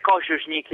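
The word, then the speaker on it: Kožušník: E Kosunik, si pronuncia così il cognome intanto? – Dovrebbe essere Kosunik in realtà, perché in ceco l'accento va sulla prima sillaba della parola normalmente.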